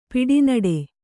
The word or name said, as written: ಪಿಡಿನಡೆ